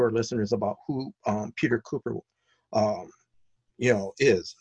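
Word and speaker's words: Kuper: Now, you know, I read one of the reviews that was from your website, and correct me on the pronunciation of the name, Peter Cupper? – Cooper. – Okay, Cooper.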